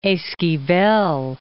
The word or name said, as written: Esquivel